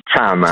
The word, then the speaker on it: Chama: Allora, cosa vuol dire Shama in swahili? A parte che non so se l'ho detto giusto. – Ecco, credo che si pronunci Chama. – Chama, ecco, allora correggiamo Chama Subito.